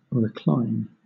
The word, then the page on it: recline: British English